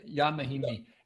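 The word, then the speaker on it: Ian Mahinmi: You know, this is now Indiana with Luis Scola and Ian Mahimi Nananudsuna, or however the hell you say his name. – Jan Mahimi. And there you go.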